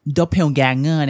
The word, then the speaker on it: Doppelganger: ี้ทั้งหน้าตาและท่าทางเหมือนฉันจังเลยเว้ยนะและปรากฏการณ์แบบนี้ฮะในภาษาอังกฤษเขาจะมีคำเรียกคำนึงครับว่า doppelganger ซึ่งไม่ใช่เหตุการณ์อะไรแปลกใหม่เลยฮะแล้วก็มีกันในหลากหลายวัฒนธรรมทั่วโลกเลยทีเดียวและวันนี้ครับนี่คือเรื่องราวของ Doppelganger ก่อนอื่นเรามาวิเคราะห์คำศัพท์กันก่อนครับ Doppelganger นะฮะ Doppelganger